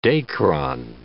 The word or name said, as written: Dacron